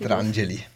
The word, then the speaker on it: Pietrangeli: Pietrangeli.